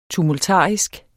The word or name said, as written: tumultarisk